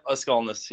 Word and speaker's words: Aukskalnis: Yep, Aukskalnis, yep.